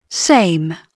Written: same

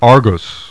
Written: ARGOS

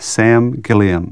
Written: Gilliam

